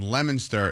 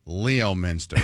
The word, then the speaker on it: Leominster: For example, Woburn is not Woburn, and Lemonster is not Leominster, right, Lisa? – I spent a lot of time in Leominster.